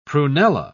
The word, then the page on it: prunella: British English